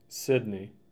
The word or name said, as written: Sidney